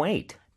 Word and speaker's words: weight: And lost some weight. – 빈칸에는 Weight, weight. – 네, wait, 기다려! 하고 같은 발음이지만 스펠링은 다르죠. 그래서 GH가 숨어 있다는 거 요거 잘 기억해 주세요.